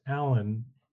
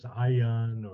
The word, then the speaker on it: Alan: And uh, shortly after arriving uh, in the first country I would serve in, uh, Ecuador, people found they couldn't quite pronounce Alan in Spanish with two L's. It came out as Ayan or something like that.